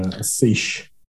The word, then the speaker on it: seiche: So now they're at this particular site, they're saying that this is probably from sloshing back and forth from like Secchi waves, from like the earthquake waves. – Yeah, that's right. That's, yeah, Seiche. – Seiche, sorry. I always want to pronounce it Secchi.